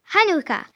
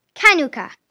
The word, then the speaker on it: Hanouka: En français, on prononce Hanouka, mais en hébreu, on le prononce Kanouka.